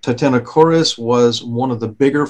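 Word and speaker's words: Titanokorys: It's Titano, like titanium, T-I-T-A-N-O, followed by the two-syllable word chorus, K-O-R-Y-S. So if you're looking it up on a search engine, that's what you would put in. Titanochoris, it was a bewildering animal.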